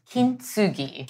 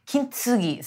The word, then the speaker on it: Kintsugi: Kintsugi. So in Japanese, typically you wouldn't say tsu. TSU is pronounced tsu. – Got it. – So very faintly with the tea.